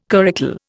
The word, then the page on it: curricle: Indian